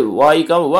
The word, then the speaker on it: YCAM: これ、 Y カム、 YCAM と書いて Y カムって読むんですね。